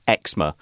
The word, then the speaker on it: eczema: Quick note: uh, in the UK, the this particular skin condition is more usually pronounced eczema.